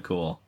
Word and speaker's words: Kuhl: I believe it's literally pronounced Chad Cool. – It's spelled K-U-H-L, not...